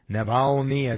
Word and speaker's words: Navalny: Navalny, I think that's the right way of pronouncing.